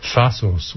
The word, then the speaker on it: Thassos: Thassos, which rhymes with Yassos.